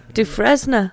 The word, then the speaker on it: Dufresne: Andy Dufresne with an S in it. – Yeah. – Dufresne.